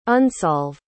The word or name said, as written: unsolve